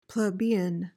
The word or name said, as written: plebeian